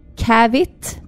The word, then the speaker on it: caveat: Aha jag besparar mig här numera för eh, hur jag uttalar det här. Alltså, mm. Cavit. – Cavit. – Cavit. Ja. Det stavas C-A-V-E-A-T.